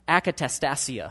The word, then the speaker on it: akatastasia: The Greek word for disorder is akatastasia.